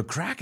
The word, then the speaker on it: craic: I didn't hear I've never heard the no crack. – Yeah, one. – What's your mind like? Oh, he's no crack. – So crack has got a lot of meaning. It's spelled C-R-A-I-C.